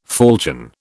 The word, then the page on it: falchion: British English